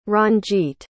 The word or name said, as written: ranjit